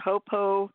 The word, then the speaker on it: Ho'oponopono: Ho'oponopono. – Ho'oponopono.